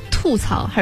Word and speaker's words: 吐槽: 今天要给大家说的最后一个词是吐槽还是吐槽？ – 吐槽。 – 咱们先来规范一下它的读音。